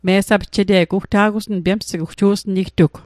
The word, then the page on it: me'sapje'te'g: Mi'gmaq